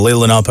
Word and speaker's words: Leelanau: On Grand Traverse Bay, Traverse City makes for a great home base for exploring wineries on the old Mission and Leelanau peninsulas. Did I get that right? – Leelanau.